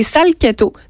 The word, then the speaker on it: Salcheto: C'est dans le sud de la Toscane, Une maison que j'adore, c'est Salchetto. Donc ça s'écrit S-A-L-C-H-E-T-O.